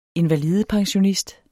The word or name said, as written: invalidepensionist